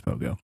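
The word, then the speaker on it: Pohgoh: How do you pronounce this? But then it's Pogo.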